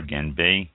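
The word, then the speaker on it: Gan B (干杯): The Gan B, I believe it's pronounced G-A-N-B-E-I, Gan B or Gan Bay, drinking ritual is a centuries-old tradition in China.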